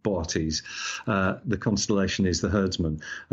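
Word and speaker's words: Boötes: It's usually pronounced Bortes, so there's two O's in it. It looks like booties, but it's Bortes. The second O has an umlaut over it to tell you that it is pronounced as a separate vowel.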